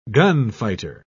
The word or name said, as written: gunfighter